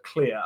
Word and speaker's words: Clear: It is clear. I kept saying Claire,